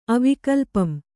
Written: ಅವಿಕಲ್ಪಂ

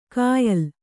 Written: ಕಾಯಲ್

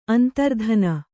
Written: ಅಂತರ್ಧಾನ

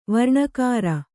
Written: ವರ್ಣಕಾರ

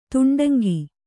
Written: ದುಂಡಂಕಿ

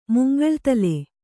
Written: ಮುಂಗಳ್ತಲೆ

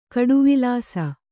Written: ಕಡುವಿಲಾಸ

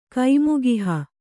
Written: ಕೈಮುಗಿಹ

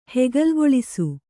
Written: ಹೆಗಲ್ಗೊಳಿಸು

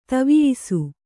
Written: ತವಿಯಿಸು

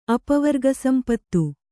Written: ಅಪವರ್ಗಸಂಪತ್ತು

